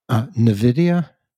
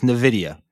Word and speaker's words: NVIDIA: Stock tip number three, and I know there's one listener out there who's going to make fun of me because I won't pronounce this right, but NVIDIA? – You nailed it.